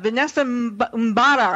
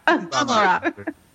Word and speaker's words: Mbamara: Mbamara.